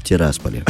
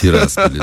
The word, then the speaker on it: Тирасполе: я э, подскажу, как надо правильно говорить? Можно? В Тирасполе. В Тирасполе, да.